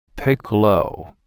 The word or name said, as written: piccolo